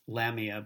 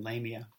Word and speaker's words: Lamia: Sometimes it's pronounced Lamia, but more often Lamia.